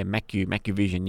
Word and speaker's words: MacuVision: Okay, over here, Blackmores, I want to ask you this, if I say it right, MacuVision, what's that? MacuVision, is that right? Macu? – Yeah, MacuVision, yeah.